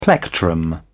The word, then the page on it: plectrum: British English